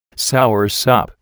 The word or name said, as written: soursop